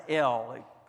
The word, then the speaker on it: El: The Hebrew name for God there is pronounced El.